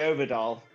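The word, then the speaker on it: Øvredal: Andre Oberdahl. – It is just Oberdahl. Okay, it's because the O with the slash through it and it threw me.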